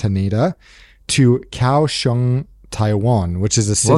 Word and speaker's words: Kaohsiung: You nailed the pronunciation. That is exactly, I just checked, that is exactly how you pronounce it. It looks like it, but it is pronounced, yeah, Kaohsiung.